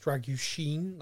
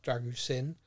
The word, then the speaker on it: Dragušin: His name is pronounced Dragušin, not Dragušin, like I've been saying.